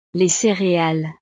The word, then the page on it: les cereales: frances